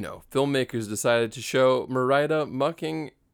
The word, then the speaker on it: Merida: But oh, sorry, how do you pronounce it? – Merida. – Oh, is that how you pronounce it? It's Merida.